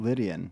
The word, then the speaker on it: Lydian: Lydian. Yeah. Like Lydia, like the name.